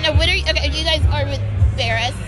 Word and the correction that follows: Verts: Verts. – Verts? – Yeah, Verts. – I was saying it wrong. – It's a little T.